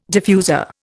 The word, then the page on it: diffuser: Indian